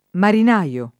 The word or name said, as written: marinaio